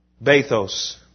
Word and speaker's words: Bathos: Bathos, B-A-T-H-O-S, long O,